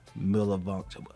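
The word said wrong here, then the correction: Milanovich: I'm butchering her name. Milanovic. There we go. Dr. Norma J. Milanovic.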